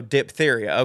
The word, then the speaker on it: diphtheria: And no, we don't pronounce the H after the P, so diphtheria, okay.